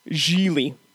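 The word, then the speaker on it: Gigli: I'll start us off with, Gili rhymes with really.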